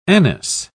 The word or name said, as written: anise